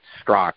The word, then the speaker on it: Straka: Well, I have interviewed Brandon Straka, or actually pronounce it Strock. It looks like Strock on paper. There's an A at the end.